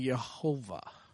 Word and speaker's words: Jehovah: Adonai, A-O-A. So what they did is they put it in between W-H-Y-H, which is Y-A-H-O-W-A-H, which pronounced would be Jehovah.